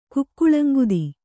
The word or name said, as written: ಕುಕ್ಕುಳಂಗುದಿ